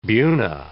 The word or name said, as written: buna